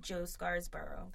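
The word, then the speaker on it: Scarborough: But then also I was watching Morning Joe, and Joe Scarsborough, that's how you pronounce his last name, right? – Yep.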